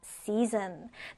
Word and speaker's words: season: season. 자, 지금 발음교를 잘 보면 zn이라고 발음으로 끝나요. zn. 그래서 season입니다.